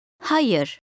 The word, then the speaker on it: Hayır: Hayır. Yer. Yer. Hayır. Hayır. – The sound ı does not exist exactly in English. It's a soft vowel sound which you will learn to discriminate and imitate.